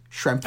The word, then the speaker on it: Schrempf: It's like a PF. It's a PF at the end, I believe. Schrempf.